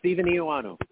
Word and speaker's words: Stephen Eoannou: So today we have with us Stefan. I just want to make sure I'm pronouncing your name correctly. – No, it's Stephen Eoannou.